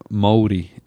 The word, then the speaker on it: Māori: Māori. M-A-O-R-I. If you were to say how it's spelled, it's like Maori or Māori. – Gotcha, Māori. – You kind of roll that R.